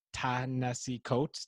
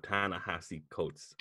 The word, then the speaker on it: Ta-Nehisi Coates: Abrams and Ta-Nehisi Coates. Did I say that right? – Ta-Nehisi Coates, I believe.